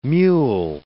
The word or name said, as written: mewl